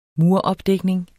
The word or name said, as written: muropdækning